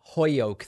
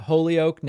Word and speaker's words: Holyoke: Okay, did you pronounce it Holyoke or Hoyoke? – No. I'm not into this whole Hoyoke thing. I pronounce it Holyoke.